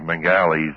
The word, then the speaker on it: Mengele: And Josef Mengele's, that's the way it's normally pronounced.